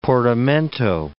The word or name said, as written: portamento